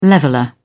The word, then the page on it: leveller: British English